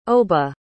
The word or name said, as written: oba